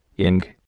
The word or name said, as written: ink